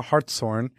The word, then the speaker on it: Hartshorne: I'm here with stand-up comedian and former plus-size model Sarah Hartshorne. Did I say that right? – Hartshorne, yeah, Hartshorne. Not Hartshorne. – Yeah, Hartshorne. It's the horn of a male deer.